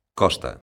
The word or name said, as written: costa